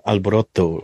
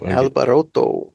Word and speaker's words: alboroto: This one's caramel corn Alborato. – Uh, Alboroto. Alboroto. Yeah, you have to like pronounce the T. You have to pronounce it right.